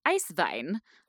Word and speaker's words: Eiswein: but ice wine or Eiswein uh, in German. – That's one German word that I can pronounce, which is pretty much what it sounds like.